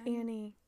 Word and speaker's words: Annie: So she would, like, have I been saying Anne or Annie? Because her name's Annie.